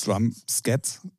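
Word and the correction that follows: Drumsheds: Nee, Heads. – Drums Heads.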